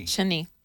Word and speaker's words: Shani: So I mispronounced your name. It's Shani. – Shani. That's all right. – I think I called you Shani. – Most Americans pronounce it. So you just go with it? – Go with it.